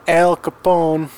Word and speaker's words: Al Capone: From Chicago, the great American city of Chicago, home of former great American Al Capone.